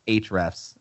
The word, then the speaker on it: Ahrefs: But from the company, it's Ahrefs is the is how they say it. The A is silent.